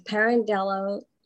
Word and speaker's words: Pirandello: He is chair of the Italian American Alliance and president of the Pirandello Lyceum.